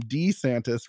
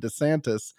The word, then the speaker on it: DeSantis: He's demanding that people call him DeSantis rather than DeSantis. Actually, I like Duh better, a nicer flow, so I'm happy he's changing it. He gets very upset when people, including reporters, don't pronounce it correctly.